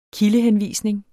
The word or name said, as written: kildehenvisning